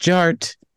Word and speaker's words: DJarTT: And my name is Jared, aka DJ Art, with two T's for a double dose of that tink tink. The D is silent, so it's just Jart.